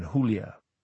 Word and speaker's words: Julia: He gave her name the Spanish pronunciation Julia.